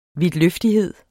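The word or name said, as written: vidtløftighed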